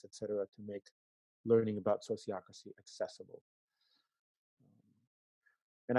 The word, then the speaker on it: sociocracy: So think of sociology and democracy, and put those together, sociocracy.